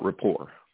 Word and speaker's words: rapport: So I just want to talk a little bit about rapport, just mostly because it's a really cool word that has a silent T at the end, and it's spelt weird.